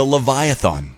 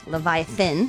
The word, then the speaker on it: Leviathan: My three roller coaster options are the Leviathan. – Leviathan, but go ahead. – Well, now we're correcting pronunciation everywhere.